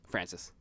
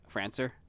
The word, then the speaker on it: Francis: Francis. – What did I say? – Francer.